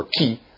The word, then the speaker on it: chi: In a chiastic structure, basically the way the thing works is, you go, and it's called a chiasm from the Greek letter chi, okay, or chi, depending on whose Greek you want to whose Greek pronunciation you want to go with.